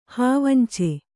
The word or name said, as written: ಹಾವಂಚೆ